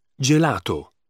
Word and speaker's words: gelato: However, when G is followed by E or I, it is pronounced G. Gelato.